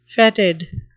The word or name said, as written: fetid